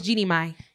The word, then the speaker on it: Jeannie Mai: I wanted to start with, how do you say her name? Is it Jeannie? Jeannie? – It's Jeannie Mai.